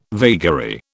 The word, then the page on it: vagary: British English